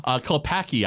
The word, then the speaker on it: Klepacki: And this is composed by Frank Kilpatrick. Oh, no. Kilpacki, I believe it is. K-L-E-P-A-C-K-I.